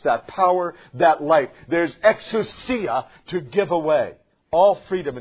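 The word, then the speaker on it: exousia (ἐξουσία): And exousia is how it's pronounced in the Greek.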